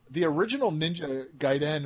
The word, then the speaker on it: Gaiden: Sorry, Gaiden, but you're supposed to pronounce it Gaiden.